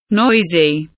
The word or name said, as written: noisy